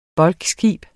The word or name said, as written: bulkskib